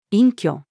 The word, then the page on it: 隠居: female